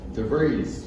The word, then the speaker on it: DeVries: Mr. Richard DeVos. – DeVries. – DeVries. Sorry.